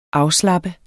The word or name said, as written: afslappe